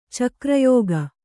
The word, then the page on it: ಚಕ್ರಯುಗ: cakra yuga